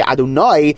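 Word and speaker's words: Adonai (אדני): So that's why when you say Hashem's name as if it's written aleph, dalet, nun, yud, you have to make the emphasis on the last syllable. You say Adonai as opposed to Ado.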